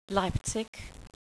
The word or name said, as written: Leipzig